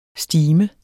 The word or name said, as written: STIMENDE